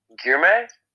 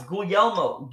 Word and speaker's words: Guglielmo: Okay, like... Guillermo? – No, it's Guglielmo. Guglielmo, are you there? – Guglielmo. – Yeah, I thought it was not pronounced like that. But no, it's an Italian name.